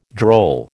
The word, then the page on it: droll: American English